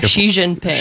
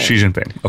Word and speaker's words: Xi Jinping: Yeah, Xi Jinping. – Xi Jinping.